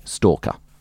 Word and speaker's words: Stalker: To find me specifically, just look for my name, Jodie Stalker. – How do you spell that? – S-T-A-L-K-E-R.